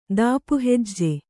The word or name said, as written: ದಾಪುಹೆಜ್ಜೆ